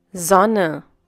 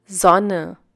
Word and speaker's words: Sonne: S, Sonne. – S, Sonne. The pronunciation of S is like the English Z.